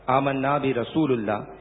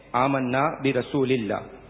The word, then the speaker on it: آمنا برسول اللہ: دوران نعت ایک جملہ پڑھا گیا آمنا برسول اللہ, یہ غلط ہے. صحیح تلفظ یوں ہے آمنا برسول اللہ.